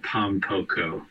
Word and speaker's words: Pom Poko: I've also been listening to a band called Pom Poko that is very sick,